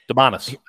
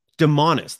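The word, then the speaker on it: Domantas: But Devontae Sabonis, and I hope I pronounced his first name correctly. – Demonis. – What is it? – Demonis. – Demonis.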